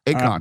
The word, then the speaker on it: Akon: Is that the way you pronounce it? – Yep, Akon.